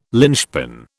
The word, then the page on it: lynchpin: British English